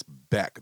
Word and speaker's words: Bek: Because like he's like blonde hair, blue eye, and yes, his name is spelled B-E-K, but it's Beck.